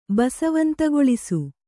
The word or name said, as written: ಬಸವಂತಗೊಳಿಸು